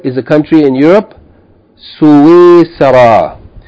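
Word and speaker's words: Suisara (سويسرا): That seen may be pronounced with sukun, as it is here written in my book, or it may be pronounced with a fatha or kasra. Suisara. It may be pronounced Suisara or Suisra. Okay, I think the more common pronunciation is Suisara.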